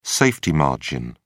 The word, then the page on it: safety margin: British English